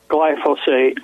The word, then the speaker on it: glyphosate: And it's pronounced glyphosate.